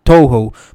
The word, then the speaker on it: Toho: Animation Studio de Tahoe. Tahoe es que se llama, lo dije bien. ¿Sabes cuál es? – Toho. Pues Toho lo, lo va a estar animando, que son los mismos que hacen My Hero Academia.